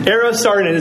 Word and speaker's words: Eero Saarinen: Especially the work, I hope I say this right, Eero Saarinen. Yes. Eero Saarinen is a Finnish architect who did a lot of work, was commissioned by Columbus, Indiana, of all places.